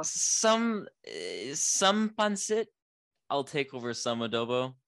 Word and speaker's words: pancit: Adobo or pancit? Is that how you say it? – Pancit. – Good, yeah. Pancit.